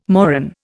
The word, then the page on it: moron: Indian